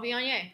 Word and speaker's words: Viognier: Is it a Vognier, Vogner? – Oh, Viognier. – That's it.